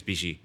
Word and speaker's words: BiXi: Traditional pronunciation is BiXi, but. Just a bit like we're kind of intentionally misunderstanding some of the things about Asian cuisine, it's totally meant to be mispronounced.